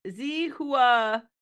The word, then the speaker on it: Zihuatanejo: I don't think that was right at all. It's Zihuatanejo. – That's not spelling it. – Yeah, okay. Zihuatanejo.